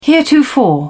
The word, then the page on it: heretofore: British English